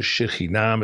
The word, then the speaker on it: Shekhinah: Dus het is de Shekhinah met de klemtoon, zoals altijd in het Hebreeuws in het algemeen, op de laatste lettergreep.